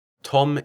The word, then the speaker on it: Tom: Tom is T-O-M, and my family name, Davis, is D-A-V-I-S.